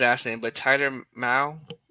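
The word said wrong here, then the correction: Mahle: Molly. – Molly, there you go.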